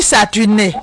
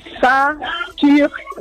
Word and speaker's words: Saturne: Mais pour prononcer Saturne, là, c'est devenu Saturné.